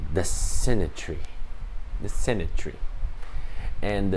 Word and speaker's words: Sinai: That Sinai and Sinai rhymes with the Hebrew word test.